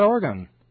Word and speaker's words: Oregon: Hey, tell us something cool about Oregon. Yeah, I said it right that time.